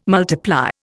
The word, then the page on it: multiply: Indian